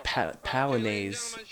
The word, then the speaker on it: Polonaise: So yeah, Polonaise is how you say it.